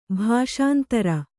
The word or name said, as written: ಭಾಷಾಂತರ